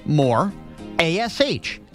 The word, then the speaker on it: Morash: It's M-O-R. Pronounce it with me. More. A-S-H. Ash. Put those together. What do you got? More ash.